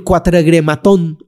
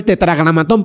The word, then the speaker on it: tetragramatón: Hay gente que hasta lo pronuncia mal. Quiero que se me active el cuatregrematón. Ah, pues eso no lo conozco yo. Conozco el tetragramatón, pero el cuatregrematón no me lo han presentado.